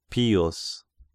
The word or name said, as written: Pius